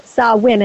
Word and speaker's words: Samhain: The festival of Samhain, and it's spelled S-A-M-H-A-I-N, is a celebration of the end of the harvest season in Gaelic culture.